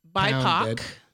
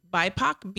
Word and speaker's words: BIPOC: They also added BIPOC. – What did you say? – BIPOC, B-I-P-O-C. – Oh my God, I thought you said something else. You're good. It rhymes with POC.